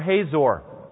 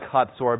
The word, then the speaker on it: Hatzor: You see it circled there, Hatzor or Hazor. I like Hatzor because I teach Hebrew, and that's the correct pronunciation in Hebrew.